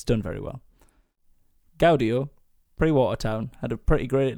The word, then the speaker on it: Gaudio: Gaudio? – Gaudio.